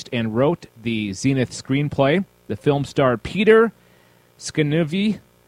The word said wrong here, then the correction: Scanavino: Hang on one second. Obviously, I can't pronounce anything. Peter okay, S C A N A V I N O. Scanavino.